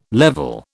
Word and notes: level: British English

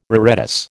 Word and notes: reredos: American English

